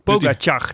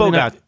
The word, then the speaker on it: Pogačar: Ik was naar Eurosport aan het kijken en daar legden ze de klemtoon op de ga. – Waarom was je nou Pogačar? Pogačar.